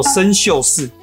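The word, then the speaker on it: 参宿四: 那么猎户座这一颗红色的星星呢，叫做参宿巳，写成国字呢就是参加的参，不过啊要念成古音参。宿呢是住宿的宿，也要念成古音，叫做宿。巳则是一二三四的四，因为啊它是中国古代二十八星宿里面的参宿的第四颗星。所以啊，称作为参宿巳。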